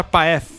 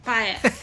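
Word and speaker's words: Paeth: Prestem atenção no nome Sacha Pett, ou Sacha Pett, eu não sei como é que fala, ele é alemão, eu não sei como é que... – Sacha Paef. – Isso aí. Paef. Que é como escreve, crianças.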